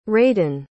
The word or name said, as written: raedan